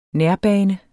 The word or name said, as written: nærbane